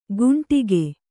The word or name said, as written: ಕುಂಡಿಗೆ